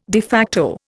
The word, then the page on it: de facto: Indian